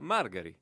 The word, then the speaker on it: Margari: dite che mi avete votato, ma non sapete neppure come si pronuncia il mio cognome. E si pronuncia Margari.